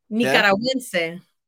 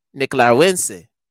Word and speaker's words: Nicaragüense: But you were saying it like, I want to say how you said it. – Nicaragüense. – Nicaragüense. – Nicaragüense, yes.